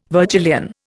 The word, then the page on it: Virgilian: Indian